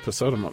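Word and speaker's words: Pesotum: Posotum. – Posotum. That's how I was going to pronounce it.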